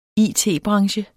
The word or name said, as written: it-branche